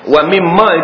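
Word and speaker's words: wa mimma (وَمِمَّا): Wa mimma itu asal ejaannya waw mim nun mim alif. Waw mim nun mim alif. Wa min ma. Wa min ma. Asalnya wa min ma. Tetapi bila nun itu mati bertemu dengan mim, maka nun itu dihazafkan, nun dimasukkan ke dalam mim, maka dijadilah dia wa mimma ejaannya.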